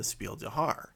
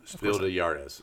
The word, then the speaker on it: Spiel des Jahres: And she was bringing up the fact that there are very few women designed games in the Spiel des Jahres. Did I pronounce that correctly? – No. Spiel des Jahres. – Jahres, excuse me.